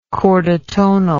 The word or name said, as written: chordotonal